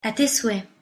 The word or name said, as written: à tes souhaits